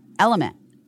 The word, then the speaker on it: LMNT: Guys, Element, in case you don't remember, Element LMNT is how it's spelled.